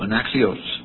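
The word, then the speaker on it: an axios (ἀναξίως): The Greek word in 1 Corinthians 11:27 is an axios with a long O. See, it's shaped like a W to those who get this note. – Yes, sir. – That's the long O.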